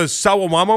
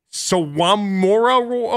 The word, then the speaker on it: Sawamura: It's called the Sawamama Award. I butchered it. Sorry, didn't come out right. Sawamura Award? Sawamura Award? I practiced this. I didn't get it right.